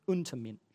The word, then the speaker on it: Untermensch: Untermensch is how it's said in German. Untermensch.